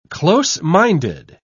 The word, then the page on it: close-minded: British English